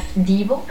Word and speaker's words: Devo: Voy a poner a Devo, que se pronuncia Divo.